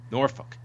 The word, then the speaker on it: Norfolk: But SmackDown from the home of Nate Milton, Virginia, Norfolk. – I think that's the right way to say it, yeah. – Norfolk.